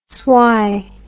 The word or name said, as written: swey